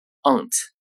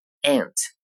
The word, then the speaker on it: aunt: 下面一个词英式英语中发作 aunt， aunt，美式英语中发作 aunt， aunt。